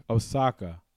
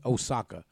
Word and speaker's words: Osaka: Osaka. Is that how you say yes. – it? – Yes. – Thanks. Naomi Osaka.